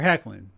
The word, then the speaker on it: Hoechlin: It's Tyler Hoechlin. The O is silent. So from now on, Tyler Hoechlin.